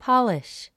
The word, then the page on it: polish: pɑ’liʃ; ポォリッシュ